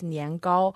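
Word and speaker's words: nian gao (年糕): But the name, the pronunciation of rice cake in Chinese is nian gao. So nian means new year and gao means high.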